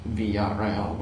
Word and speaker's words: Villarreal: We pronounce it Villarreal, by the way, Pete, so we get complaints if we don't pronounce non-Argentine names. Non-Argentine.